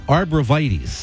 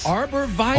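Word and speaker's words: arborvitae: I used to say arboretives, right? And we're professional radio people. We should know how to say it, right? – Right. – But my wife, the green thumb gardener plant manager, arborvitaes. – Arborvitaes. – Arborvitaes. Like arbor, like a tree is arbor.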